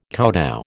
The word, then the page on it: kowtow: American English